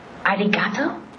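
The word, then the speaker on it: Arigato (ありがとう): How do you say thank you in Japanese? I can't remember. – Arigato? – Perfect. It was arigato.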